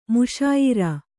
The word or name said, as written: ಮುಶಾಯಿರಾ